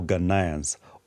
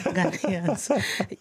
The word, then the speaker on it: Ghanaians: Are the constitutional, the proposed constitutional changes for Ghanaians, or is it for the political elite? – I like how you pronounce Ghanaians. – Yeah. – Ghanaians. Yes.